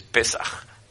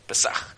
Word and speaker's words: Pesach: Of hoe het beter uitgesproken wordt is Pesach. Pesach. Dat is de Hebreeuwse manier.